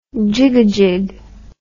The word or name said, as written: jigajig